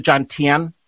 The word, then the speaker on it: John Tien: And you introduce us to Secretary, is it John Tien? Is that how you pronounce his Correct.